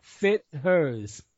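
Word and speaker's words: Fithers: And now again, it's spelled FIT for fit, Yes. – HERS for hers. – Yes. Correct. – Fit hers. Never heard of it before in my life, but that's great.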